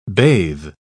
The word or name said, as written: bathe